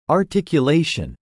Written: articulation

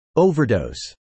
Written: overdose

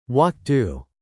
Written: walk to

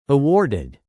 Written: awarded

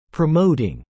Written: promoting

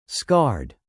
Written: scarred

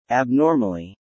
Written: abnormally

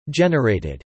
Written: generated

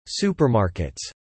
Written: supermarkets

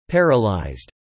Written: paralyzed

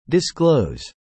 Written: disclose

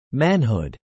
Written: manhood

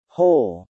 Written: hole

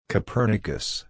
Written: Copernicus

